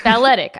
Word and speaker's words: balletic: Balletic. I think that's how you pronounce it, like a ballerina.